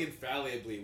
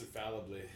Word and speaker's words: infallibly: You keep saying infallibly when it's pronounced infallibly.